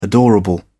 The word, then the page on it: adorable: British English